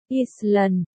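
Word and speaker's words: Ísland: Iceland, tiếng Iceland, Island phát âm, Island,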